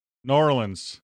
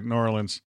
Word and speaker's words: New Orleans: New Orleans. – Nice. – That's how you say it, New Orleans.